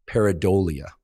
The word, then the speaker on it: pareidolia: But the correct pronunciation is pareidolia.